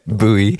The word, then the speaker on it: Bowie: A David Bowie cover, as he says it on the record. – Yeah. – Bowie. – That's one of three acceptable pronunciations on this show.